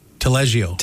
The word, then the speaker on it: Taleggio: But they've got the drawing room has a, am I saying this right? Taleggio dip? – Taleggio. – Taleggio. – Close.